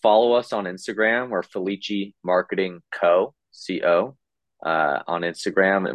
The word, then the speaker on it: Felice: It's spelled Felice, but it's pronounced Felici.